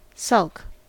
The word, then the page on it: sulk: American English